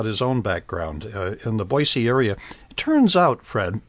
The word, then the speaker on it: Boise: Boise. And by the way, some people pronounce it with a Z in the middle. And oh, no, it's Boise.